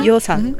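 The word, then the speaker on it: Yeosang: We've got Wooyoung and Yosan. – Yosan. – Is that how you say it? – Yeah.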